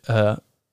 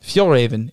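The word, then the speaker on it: Fjällräven: Later, he started a company named Fjolraven. Fjolraven. I think he's Nordic. – For sure he's Nordic. – Yeah. Ak Norden. Yeah. Fjolraven, because like the J's are pronounced as Y's.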